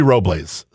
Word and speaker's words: Robles: It's supposed to be Robles. So if you say it Robles, yeah, you're doing it right.